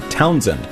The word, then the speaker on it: Townsend: T-O-W-N-S-E-N-D, Townsend. It rhymes with godsend.